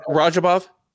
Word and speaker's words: Radzhabov: I know it's not pronounced how it looks. It's Radzhabovic or something like that. How do I say it? – Radzhabov. – Radzhabov, yeah. See, that's I'm way off.